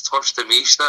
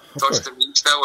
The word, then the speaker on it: torta mista: Another thing that I really like that's a Portuguese dish, bro, is it's torta mista. Am I saying that right? – Yeah. – Torta mista, where it's just ham, cheese on a popsicle, kind of pressed down a little bit, warmed up.